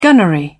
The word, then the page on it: gunnery: British English